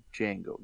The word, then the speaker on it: Django: This the Django. The J is silent.